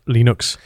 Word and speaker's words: Linux: Well, I think it was a famous recording of how to pronounce Hello, Linux. My name is Linus Torvalds, and I pronounce Linux. Linux.